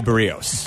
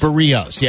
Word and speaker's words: Berríos: Javi Baez is brother-in-law's with, how do you say the Toronto pitcher? It's Jose Berríos. – Berríos, yeah.